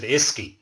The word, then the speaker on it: uisce: U-I-S-C-E pronounced it Iski and uh, mm.